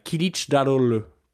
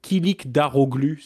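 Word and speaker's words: Kılıçdaroğlu: c'est monsieur Kemal Kilich Darol comme on le prononce en, turc, ou euh, Kilik Daroglu, si tu voulais que je le lise.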